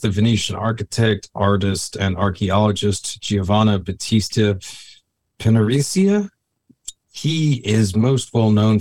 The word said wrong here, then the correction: Piranesi: Can I do the pronunciations? – Yes, sir. Yes, go for it. I'm probably butchering that That's one. That's all right. So before it was Schaffhausen, and then now we're Giovanni Battista Piranesi. – Piranesi. Okay. Thank you.